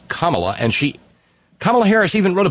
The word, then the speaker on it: Kamala: My name is pronounced Kamala. And she even phonetically spelled it for the maggots and whatnot out there. C-O-M-M-A hyphen L-A. Kamala.